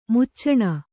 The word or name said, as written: ಮೋಚನ